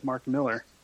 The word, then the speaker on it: Millar: Or actually, the most mispronounced one was people who said Mark Millar instead of Mark Miller. – Mm. – That got a lot of people back in the day. – So it is Miller.